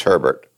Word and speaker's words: Herbert: The European Lucas Erbe, as might be how it's pronounced, as opposed to Lucas Herbert. I think he's French, so that would be Lucas Erbe, as opposed to the guy, the French guy, in the pitch for the for my little training aid.